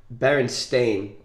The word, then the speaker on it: Berenstain: So this example wouldn't be that good for you, but when it hit me, it's actually spelled Berenstain. – S-T-A-I-N.